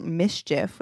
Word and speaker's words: MSCHF: There's this company called Mischief, which is spelled M S C H F, and apparently they've done some other weird pranky things before.